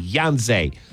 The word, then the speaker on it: Yhonzae: Pierre is it's spelled Y-H-O-N-Z-A-E. Yonze.